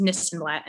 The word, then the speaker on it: Nissenblatt: I am at Ari this and that, A-R-I this and that, because my last name is Nissenblatt and it rhymes.